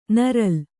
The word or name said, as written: ನರಲ್